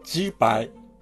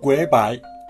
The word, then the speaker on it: 鶏排: 台湾では、ジーパイ。ビンナン語では、グエバイ。広東語では、ガイパーって感じでしょうか。ジーパイは、鳥のステーキという意味のようです。